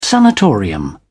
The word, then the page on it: sanatorium: British English